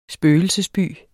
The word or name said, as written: spøgelsesby